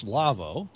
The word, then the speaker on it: Lawo: Their website is LAWO, pronounced Lavo.